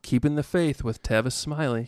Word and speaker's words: Tavis Smiley: Tavis Smiley, is that how you say his name? – Yeah, Tavis Smiley.